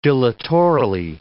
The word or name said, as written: dilatorily